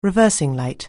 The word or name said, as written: reversing light